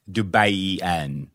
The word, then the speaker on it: Dubaian: Dubaian. That's how you pronounce it. – Okay. Thank you.